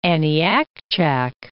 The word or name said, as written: Aniakchak Crater